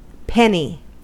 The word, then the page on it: penny: American English